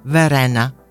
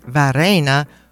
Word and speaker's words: Varenna: For example, the village of Varenna on Lago di Como, I have literally pronounced it Varena for about 25 or 30 years, and it wasn't until my friend from Chernobyl corrected me just last fall.